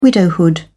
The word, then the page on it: widowhood: British English